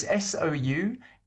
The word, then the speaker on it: Souvenaid: How do you spell it? – So it's Souvenaid. So Suvenaid.